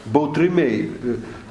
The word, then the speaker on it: Baltrimėjai: Jie tiesiog kietą L taria, kaip ir Lenkiai, kaip ir gudai, taria, kartais gali ištarti kaip tą tokį nu, neskaimeninį U. Bautrimėjai.